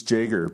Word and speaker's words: Jager: It's Jager, but it's only been 5 years, really.